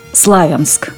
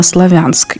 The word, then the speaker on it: Славянск: И объясняя такое ударение, они говорили, что «Славянск» апеллирует к слову «слава». То есть название города связано не со словом «славянский», что, в общем-то, правильно, а со словом «слава».